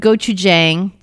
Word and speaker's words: gochujang: Well, I put gochujang. Did I say that right? Did I actually pronounce that right this time? – I think gochujang. – So. Or gochujang. – I usually like trip over my tongue on that. – Well done. Progress.